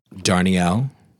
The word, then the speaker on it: Darnielle: You brought us an album this week that is by John Darnielle.